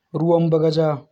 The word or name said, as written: Ruwan Bagaja